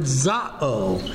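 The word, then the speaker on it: za'o (ζάω): The word live, we saw last time, is the word za'o. Z-A-O. That's the verb form. Here we actually have zoe. This is Z-A-O, the noun form.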